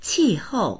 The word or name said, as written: qi4hou4